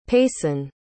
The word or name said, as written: paisyn